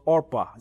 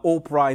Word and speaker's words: Orpah: Her name was Orpah. It's my understanding that's where we get the name Oprah. They kind of mispronounced it and they called her Oprah instead of Orpah.